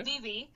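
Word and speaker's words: cici: So the word Zizi is the Fijian word to run, and it's C-I-C-I. The C is pronounced like a T-H sound, so okay. Zizi.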